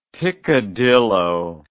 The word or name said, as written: picadillo